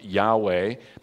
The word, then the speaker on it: Yahweh: would be pronounced Yahweh, but you're not supposed to say that.